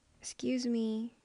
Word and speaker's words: excuse: Or you can also make the first letter E as like silent letter. So use X, just start from X. Excuse me. So not more of X, but more of S.